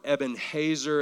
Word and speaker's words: Ebenezer: 1 Samuel 7:12 says, then Samuel took a stone and set it between Mizpah and Shen and named it Ebenezer, saying, thus far the Lord has helped us. Now the word Ebenezer or Ebenezer or Ebenhazer, as it's more closely pronounced, it means the stone of help.